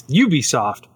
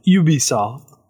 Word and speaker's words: Ubisoft: But anyways, yeah, that's why it's Ubisoft, but the French pronounce it Ubisoft.